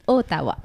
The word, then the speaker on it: Ottawa: Alors, prononciation anglais, attention! Aroa, Yeah, yeah mesdames et messieurs, this is Ottawa.